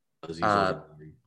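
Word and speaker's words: Azeez Ojulari: At 17, I have the Raiders bolstering their edge and getting Aziz Ojulari. Is that how you pronounce his name? – Yep. – Aziz Ojulari.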